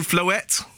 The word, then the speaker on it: Floette: It's called Floette. That's how it's pronounced, guys, in case you're confused.